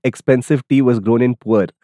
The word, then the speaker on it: Pu'er: I will spell it first before I attempt to pronounce it. P-U-E-R Hong Yin Yuan Cha. – So puer. – Puer.